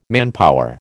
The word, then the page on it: manpower: American English